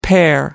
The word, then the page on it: pair: American English